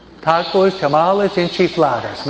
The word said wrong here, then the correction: enchiladas: Ah, siempre lo digo mal, ok. Pero son chiflados por enchiladas, ok.